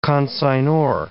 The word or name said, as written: consignor